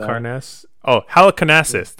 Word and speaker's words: Halicarnassus: Halicarnassus. I used to know how to say it, but Halicarnassus. That's right.